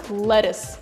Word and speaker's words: lettuce: И читается оно как lettuce, а не то, что вы там сказали. Не латюк, не латюс, а именно lettuce.